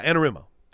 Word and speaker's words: Anarumo: Yeah, Anarumo. Did we say it right? – That's the official pronunciation.